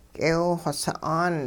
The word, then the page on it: k'eew-xw sa'aan: hupa